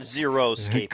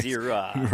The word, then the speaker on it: xeriscaping: Well, because nobody can pronounce it correctly. It's not xeriscaping. Correct. It's Zira.